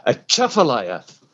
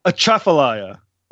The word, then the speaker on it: Atchafalaya: Yeah, so I'll tell you the easiest way to remember that is it's like you're sneezing. You go, Atchafalaya. – Atchafalaya.